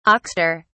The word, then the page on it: oxter: British English